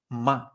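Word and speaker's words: ma (妈): En este caso, primer tono es esta línea horizontal y se pronunciaría ma, ma. Repite conmigo. Ma, Perfecto, si te fijas, es como un tono agudo, ¿vale? Y continuo. Ma, es como el sonido de, un tren cuando pita.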